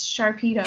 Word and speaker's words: Sharpedo: I get corrected on it every time, but it's Sharpedo. – Sharpedo, really? – Yeah.